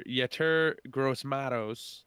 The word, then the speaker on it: Yetur Gross-Matos: And we have edge rusher Yetur Gross-Matos, I believe is the pronunciation. Many of people have mentioned it in different ways, but we're going to stick with that.